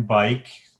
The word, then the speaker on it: Byk: My name is Ken Bike, pronounced like a bicycle and not spelled that way.